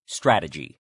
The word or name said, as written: strategy